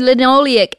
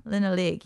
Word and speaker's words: linoleic: It's the conjugated linoleic, how do you say that? – Conjugated linoleic acids. – Yeah, linoleic, yes.